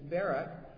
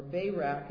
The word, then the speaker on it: Barak: She uses a gentleman to help her in the battle, and his name is Barak, or Barak as some would pronounce it, and he's listed as your fifth judge.